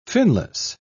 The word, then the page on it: finless: British English